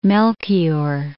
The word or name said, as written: Melchior